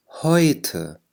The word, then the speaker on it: heute: heute heute قلنا اي او تلفظ اي، أخذناها في درس الـ 11 في قسم القواعد.